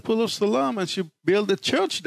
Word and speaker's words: Siloam: there's many different ways of pronouncing it. I pronounce it Siloam, however you want to pronounce it.